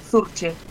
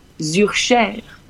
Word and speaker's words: Zurcher: pues mira, en español, digamos, sería Zurcher, tal cual está escrito. – Zurcher. – Ahí, tal cual. – ¿Y si no es en español? – Pues mira, claro, depende a dónde nos vamos. En francés, que eu son da parte, bueno, son da parte francesa de Suiza, de Ginebra, Zurcher.